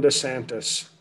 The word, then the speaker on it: DeSantis: DeSantos? Is that how you pronounce his name? – No, I wouldn't support him if he had a Latino name. Ron DeSantis. – DeSantis, that's what I thought.